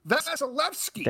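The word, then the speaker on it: Vasilevskiy: And I said it right. Vasilevsky. – There it is.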